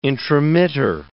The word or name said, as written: intromitter